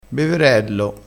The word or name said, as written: beverello